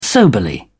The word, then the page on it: soberly: English; British English